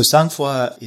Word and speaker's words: sangfroid: And I don't know if I'm going to say it right, is sangfroid.